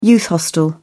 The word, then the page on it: youth hostel: British English